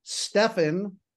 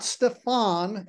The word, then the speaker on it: Stefan: So the name is Stefan, not Stefan. Everybody thinks the accent should be on the second syllable because the last name, everybody thinks the name should be exotic.